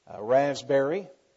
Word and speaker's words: Rasbeary: When you have an unusual name like mine, Raspberry, R-A-S-B-E-A-R-Y. And make sure you spell it right. Mrs. Raspberry gets offended. But people ask me, how do you say your name? Just like it's spelled.